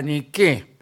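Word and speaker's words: Nike: Una revolución eh, que tuvo consecuencias políticas muy importantes, que se la conoció como la revolución de la Niqué, porque Niqué, Niqué era lo que gritaban revolucionarios. Y ni qué quiere decir victoria.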